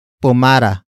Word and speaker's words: pumara: In this context, the na particle introduces what the driver should do, and that is pumara, pumara, pumara. Pumara is spelled as P-U-M-A-R-A.